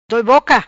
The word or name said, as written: Doi Boca